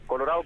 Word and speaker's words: Colorado: Claro, Yuyo Colorado, como pronunciamos en el norte, sin D.